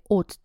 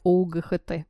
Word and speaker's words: ought: И посмотрите, если после should мы частичку to не употребляем, то после ought to употребляем. Ought пишется O-U-G-H-T вот это любимое сочетание всех студентов, которые учат неправильные глаголы, которые все в тайну говорят ОУГХТ. вот как раз оно в этом модальном глаголе и встречается. O-U-G-H-T, ought to.